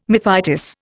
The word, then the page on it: mephitis: Indian